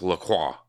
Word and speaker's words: LaCroix: Like with LaCroix, like, you know, they call it LaCroix. So they're doing half the work, the Americans sometimes. They're like, we're not going to call it LaCroix. But then they're like, we know there's an accent in there, so we're going to call it LaCroix. But in reality, that word is pronounced LaCroix.